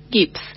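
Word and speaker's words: Gipps: Ruth Gibbs, el apellido se escribe G-I-P-P-S. g i p s P.P.S. Gibbs.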